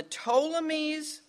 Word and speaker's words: Ptolemies: the Ptolemies, which starts with a P, but the P is silent, the Ptolemies of Egypt and the Seleucids of Syria.